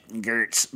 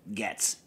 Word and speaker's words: Goetz: For those of you that have never been to the Northeast or, you know, Pennsylvania or anything, Getz is spelled G-O-E-T-Z. So it should be pronounced Gertz, but it's Getz.